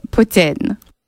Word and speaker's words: poutine: And for any real purists out there, I suppose I should also give the alternate pronunciation of poutine, which is, of course, poutine.